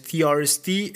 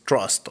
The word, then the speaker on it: TRST: Me corrigieron la pronunciación, de hecho no es TRST, es Trust. O bueno, puede ser TRST o también puede ser Trust.